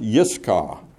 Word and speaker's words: Iscah: Now, Iscah in Hebrew starts with a Y, not an I, and it's pronounced Yiscah in Hebrew, and it's a word that has to do with sight, with seeing.